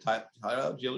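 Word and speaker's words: Allgeier: Tyler Algier is correct.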